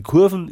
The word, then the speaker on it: Kurve: Die Kurven ist die bayerische Aussprache des schriftdeutschen Kurve.